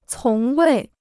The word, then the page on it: 从未: cóng wèi